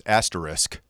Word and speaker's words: asterisk: The correct pronunciation is asterisk, but lots of people put an X in there, even though there's no X in it.